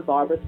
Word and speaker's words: Barbra: Barbra spelled like Streisand, B-A-R-B-R-A, Gilman with one L.